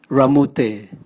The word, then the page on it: ramote: Raga